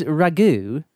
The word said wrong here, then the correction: Raguet: No. – Ragay? I'll say Louise Spell it! – R-A-G-U-E-T. Regeu.